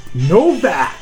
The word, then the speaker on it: Nowack: He said it's pronounced Novak. So, congratulations, Matt. – Let's make a big deal about this. Now, his last name is spelled N-O-W-A-C-K. So, isn't it Novak? – Excuse me, while people from my part of town, we pronounce it Novak.